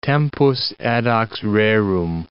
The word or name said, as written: tempus edax rerum